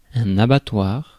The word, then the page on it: abattoir: France; [a.ba.twaʁ]